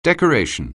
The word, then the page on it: decoration: anglais